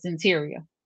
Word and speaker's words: Cyntiria: The pronunciation of the name is Cyntiria.